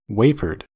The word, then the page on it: wafered: American English